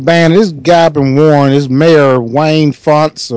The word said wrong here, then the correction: Fouts: No, I said the name wrong. I'm sorry. His name is Jim Fouts.